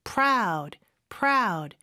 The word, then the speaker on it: proud: 자, 일단 proud라는 단어부터 보면 PR로 시작하죠. P 발음은 P가 아니에요. P, 요런 소리예요. 그래서 proud, R 발음으로 연습해 주신 다음에 proud, 이렇게 살짝 넣는 연습해 주시면 될 것 같아요. proud, proud. 그래서 P하고 R 하나의 자음처럼 들려야 합니다.